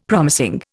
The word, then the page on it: promising: Indian